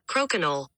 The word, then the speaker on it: Crokinole: Crokinole.